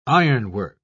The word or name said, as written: ironwork